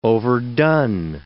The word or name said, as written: overdone